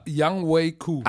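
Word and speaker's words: Younghoe Koo: Die laufen den Ball, bei drei Sekunden schießen das Game-Winning Field go Young Hu Ku. – La Yang Wei Ku. Ach, so wird er ausgesprochen.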